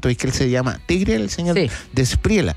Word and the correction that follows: De la Espriela: ¿así se pronuncia? ¿Despriela? – De la Espriela.